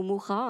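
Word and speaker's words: Mucha: C'est une œuvre remarquable de Hokusai qui a été réinterprétée par un artiste contemporain qui s'inspire à la fois de Musha donc Musha qui se, présente, qui se prononce Musha ou Moura, hein, selon le pays,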